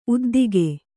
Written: ಉದ್ದಿಗೆ

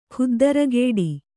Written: ಖುದ್ದರಗೇಡಿ

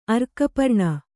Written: ಅರ್ಕಪರ್ಣ

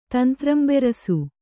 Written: ತಂತ್ರಂಬೆರಸು

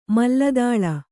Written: ಮಲ್ಲದಾಳ

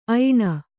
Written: ಐನ

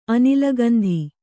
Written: ಅನಿಲಗಂಧಿ

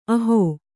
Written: ಅಹಿ

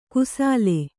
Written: ಕುಸಲೆ

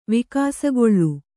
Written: ವಿಕಾಸಗೊಳ್ಳು

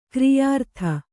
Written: ಗಿರಿಯಾರತಿ

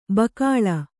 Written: ಬಕಾಳ

